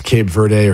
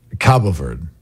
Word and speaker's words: Cabo Verde: I'm saying, by the way, it's pronounced both ways. It's pronounced Cape Verde or Cape Verde, but the Portuguese pronunciation is Cabo Verde.